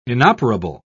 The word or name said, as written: inoperable